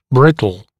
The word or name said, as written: brittle